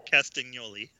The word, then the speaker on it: Castagnoli: Castagnoli. Uh, Castagnoli. There you go.